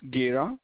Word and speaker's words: Gera: Yeah, it's Gera. It's spelled as G as in George, E as in Edward, R as in Robert, A as in Adam.